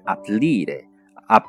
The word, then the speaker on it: aprire: 后面接着是，呃，开开门啊，或者是开打开的这个 apre 文中的这个 apre 呢，相当它的原型是 aprire，ap aprire，aprire，嗯，舌头小舌头要动一下，aprire，在意大利中叫做大舌音，但是这个呃发音可能会比较难一些，需要去去。